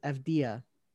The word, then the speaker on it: Avdija: Pronounce it Denny, you got that right. But the last name is Avdija.